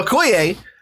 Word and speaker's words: Okoye: Well, how do you pronounce it? Okoye. That's right. Okoye.